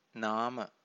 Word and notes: nama: Pali

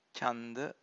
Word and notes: chanda: Pali

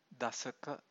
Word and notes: dasaka: Pali

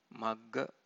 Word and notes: magga: Pali

